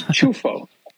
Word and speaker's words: Ciuffo: I think the proper pronunciation they tell me, and you, I know you have plenty of real Italians listening, is Ciuffo. It's uh-huh. Ciuffo.